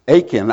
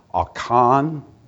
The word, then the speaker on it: Achan: That God said, These things I have declared to be destroyed and, uh, and they broke faith with God uh, for Achan. I, I, I, was trying to look up, see how that's actually pronounced, and it's pronounced sort of like Achan, uh, but Achan seems everybody knows who Achan is.